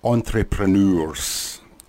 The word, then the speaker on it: entrepreneurs: It's E-N-T-R-E-P-R-E-N-E-U-R-S. Entrepreneurs. It's four syllables. It's actually not a typical English word. It's more, it sounds more along the lines of a French word.